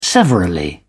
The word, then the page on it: severally: British English